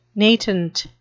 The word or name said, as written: natant